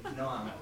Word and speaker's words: Noam: Noam. – Noah? – N-O-A-M. Noam. – N-O-A-M.